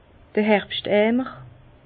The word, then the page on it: d'r Herbschteimer: Bas Rhin; Herrlisheim